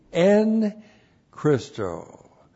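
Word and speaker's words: en Christō: E-N-C-H-R-I-S-T, long O. That's the dative. Christos is the nominative. Christo is the dative. En Christo.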